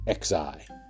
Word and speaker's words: Xi: Remember, the Chinese president's name is Xi, pronounced Xi.